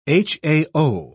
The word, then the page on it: hao: British English